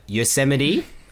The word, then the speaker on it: Yosemite: I think, like, now I need to make sure in my mind that I say it like the word and not like Vegemite, because they're spelt very similar. Yosemite, looks Yosemite?